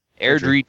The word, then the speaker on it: Airdrie: Airdrie toddler holding BB gun causes a stir.